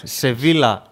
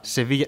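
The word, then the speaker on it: Sevilla: Βίλα Ρεάλ, Βίγια, οκ, διαβάζεται στην Ισπανία. Σεβίλα, Σεβίγια. Ναι, αλλά γράφεται και το ίδιο.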